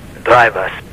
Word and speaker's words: Blajwas: Blajwas. B-L-A-J-W-A-S. – Okay. – The J is pronounced as an I in Poland.